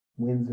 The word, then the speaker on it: Wyns: Wins. Wins is spelled W-Y-N-S.